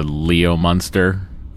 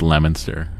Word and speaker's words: Leominster: it's like when people from not Massachusetts try to pronounce Massachusetts towns, and they call, like, Leominster Leo Munster. Uh-huh. And it's just, no, it's Leominster.